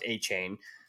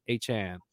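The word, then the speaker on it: Achane: They're like, it's pronounced A-Chain. The Dolphins, I believe, said A-Chain a lot.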